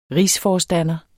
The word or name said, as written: RIGSFORSTANDERE